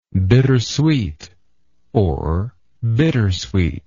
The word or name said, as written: bittersweetness